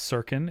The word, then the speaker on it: Sirkin: It's super easy. It's Sirkin. It's just the way it's written, S-I-R-K-I-N.